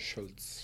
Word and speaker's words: Shults: Is there an L? – It's an eight S H U L T S Schultz. – Schultz.